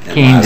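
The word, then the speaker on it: Kehne: Well, how do you get by Keenan Lazarek? Assuming you say it, the proper pronunciation is Keen.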